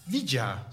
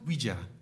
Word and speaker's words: Ouija: Ouija, zeg ik dat goed? Ouija, toch? Ik denk het wel, ja. Ouija.